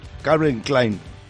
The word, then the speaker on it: Calvin Klein: Se dice Calvin Klein.